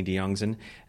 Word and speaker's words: Diongzon: So S-I-D-N-E-Y, not S-Y. And then my last name, this is a funny one, D-I-O-N-G-Z-O-N. Or for people outside the States, Z-O-N.